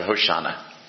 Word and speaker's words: Hoshana: Which is just the mispronunciation of Hoshana.